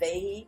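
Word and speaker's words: Fage: It's called, it's pronounced Fahe. It's F-A-G-E.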